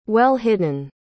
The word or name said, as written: well-hidden